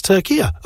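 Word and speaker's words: Türkiye: I thought, he said it wrong. Turkia. And then he kept saying, I'm like, oh, right, okay, it's Turkia.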